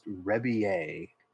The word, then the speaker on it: Rebillet: I believe it's pronounced Rebillet, but the only way I can remember to spell it is by saying Rebillet. – Rebillet. – Rebillet. And I heard him pronounce it, and I was like, oh, Rebby. I'm like, wow, I would not have said that myself, because clearly I'm not taking the French influence there.